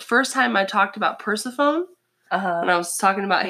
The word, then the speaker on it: Persephone: Also, future reference, Persephone is pronounced Persephone. – Persephone.